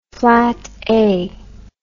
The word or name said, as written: flata